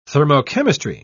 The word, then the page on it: thermochemistry: British English